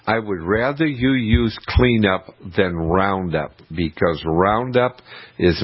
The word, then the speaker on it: KleenUp: But new shoots coming out of the ground, you can get cleanup. And it's spelled K-L E-E-N-U-P.